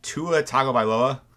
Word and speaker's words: Tua Tagovailoa: Tell me if I'm pronouncing his name right. Tua Tagovailoa. – I think that's correct. I don't know how to pronounce it myself, but I'm pretty sure that's close.